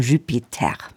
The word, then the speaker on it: Jupiter: Thomas Dunford hat für diesen Abend auch sein Ensemble Jupiter, beziehungsweise französisch ausgesprochen Jupiter, mitgebracht.